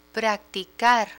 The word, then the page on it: practicar: Spanish